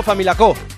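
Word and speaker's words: Famalicão: Se escribe Familacao, pero se lee algo así como Familacó.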